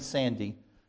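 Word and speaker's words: Sande: Is it Sandy? Okay, good, because you're all I've ever done is read it, so I don't know if it's Sandy or Sand, but so let's say Ken Sandy.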